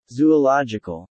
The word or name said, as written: zoological